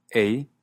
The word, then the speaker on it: eh: Para terminar con la cuestión gramatical, tenemos la famosa partícula A al final de frase, como por ejemplo en This podcast is awesome, eh? Por cierto, A se escribe E-H.